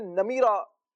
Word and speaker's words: نمیرا: اصل یہ تلفظ نمرا کے بارے میں جو انہوں نے کہا نا، تو یہ عربی میں نمیرا یہ ہے تلفظ،